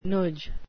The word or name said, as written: noodge